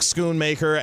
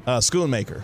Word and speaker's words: Schoonmaker: Because I always want to pronounce that H like Schoon, Schoonmaker or Mocker.